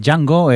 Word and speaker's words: Django: Django, eh, la D no se pronuncia, que es muda, que se ve que estamos pronunciando la D.